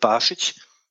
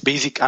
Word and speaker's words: Bašić: Nur wenn man sich zwei Beispiele anguckt, so von mit den erfolgreichen Blogs, die wir so kennen, das ist von Robert Bašić, Robert, ausgesprochen, viele sprechen das als Basic an, der hatte einen Mischblock, der hat ja über alles damals geschrieben, bis er das verkauft hat, was ihm unter die Finger kam.